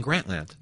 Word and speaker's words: Grantland: Have I been pronouncing Grantland wrong all these years? I've been saying Grantland. – No, I think we accept either pronunciation. – Okay. – There's a bit of a schwa on the second syllable sometimes, if you're kind of rolling it that way.